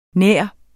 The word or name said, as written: nærmere